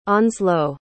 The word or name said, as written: onslow